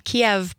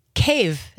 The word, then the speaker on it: Kyiv: And you are the founder and the CEO of Ukrainian Patriot, which is a nonprofit based in Kyiv, not Kyiv, not Kiev, but Kyiv.